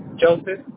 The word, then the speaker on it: Joseph: Joseph. – Johnson? – J-O-S-E-H. – J-O-S-E-H. So, pronounce it for me. – J-O-S-E-P-H.